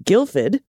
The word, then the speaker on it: Guildford: Just up the road is the town of Guildford, which is pronounced Guildford.